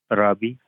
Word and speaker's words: Rabi: Robbie, R-A-B-I. – Robbie. Did I pronounce that correctly? – R-A-B-I is my first name.